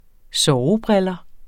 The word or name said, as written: sovebriller